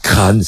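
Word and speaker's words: Craonne: Et au pied du Chemin des Dames, il y a ce village qui a été reconstruit, qui s'appelle, on va dire Crane, c'est difficile à prononcer.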